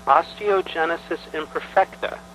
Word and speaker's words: osteogenesis imperfecta: Now, Mary Beth, your wife, suggested I talk to you, and you agreed, and you were born with osteogenesis imperfecta.